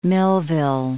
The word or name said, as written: Millville